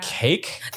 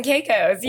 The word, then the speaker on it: Caicos: Which I did not know how to pronounce for the longest time. – Turks and Caicos. – I was like, cake?